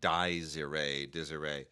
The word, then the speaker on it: Dies Irae: Desiree, the complete series, spelled like D-I-E-S-I-R-A-E, like Desiree.